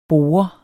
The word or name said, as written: borde